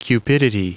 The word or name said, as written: cupidity